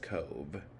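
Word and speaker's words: Cobh: Cove, pronounced Cove, approached.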